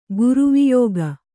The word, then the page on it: ಗ್ರೈವೇಯಕ: grivēyaka; Kannada